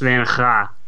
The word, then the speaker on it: Van Gogh: It's pronounced Van Ga.